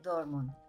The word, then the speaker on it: Dortmund: Dortmund.